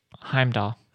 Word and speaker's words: Heimdall: And Heimdall. Is that how you say his name? Heimdall? – Heimdall.